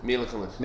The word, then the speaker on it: Kunis: Mila Kunis.